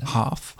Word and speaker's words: Hough: How about a shout out to a town or city called, Huff, H-O-U-G-H. How would you say that? – Hoff, Ohio.